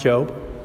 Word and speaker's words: Job: In the book of Job, which is spelled like Job, but it's in the Old Testament, it's pronounced Job.